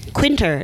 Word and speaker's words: Quinter: Quinter. It's like winter with a Q.